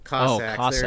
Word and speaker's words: Cossacks: Am I pronouncing it wrong? I might be pronouncing it wrong. – Cossacks? – Cossacks. There it is.